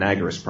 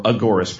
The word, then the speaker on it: agorist: One is called An Agorist Primer or Agorist Primer. He used both pronunciations, so I prefer agorist because it sounds more like anarchist, but obviously agorist is closer to the agora, which is what it's based on.